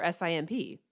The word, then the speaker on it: SIMP: Is it SIMP or S-I-M-P? – I personally think it's S-I-M-P.